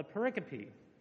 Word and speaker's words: pericope: One, a set of verses that form a single unit or thought is called a pericope. And if you pronounce it pericope, your pastor will laugh at you.